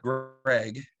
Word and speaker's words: Graig: Well, Twitter-wise, it's spelled Graig, as in ITS spelled, and then GRAIG.